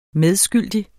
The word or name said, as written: medskyldig